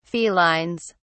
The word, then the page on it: felines: British English